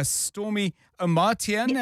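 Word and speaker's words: Stormie Omartian: It's called The Power of a Praying Woman by Stormy Omartian. Have I said that right? – Yes, you said it right.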